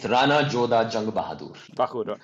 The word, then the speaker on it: Rana Jodha Jung Bahadur: but he's a gentleman known as Rana Jodha Jung Bahadur. I don't know whether that's the correct pronunciation. – Yeah. Okay, it's Rana Jodha Jung Bahadur.